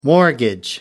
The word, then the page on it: mortgage: American English